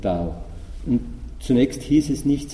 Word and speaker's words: Dao: Das berühmteste ist das Wort Dao oder Do, ja, japanisch ausgesprochen Do.